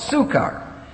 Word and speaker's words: Sychar: First of all, he came to a city called Sychar, and that's how you pronounce it, Sychar.